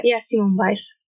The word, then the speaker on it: Simone Biles: Her name is Simone Biles. – Yeah, Simone Biles.